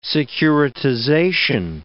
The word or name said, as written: securitization